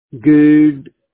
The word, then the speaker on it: Goode: Good. – What was it called? Can you spell that? – G-O-O-D-E. – V-O-O-D-D? – G-O-O-D-E. E like it. – Ah. Yeah, like this E is silent.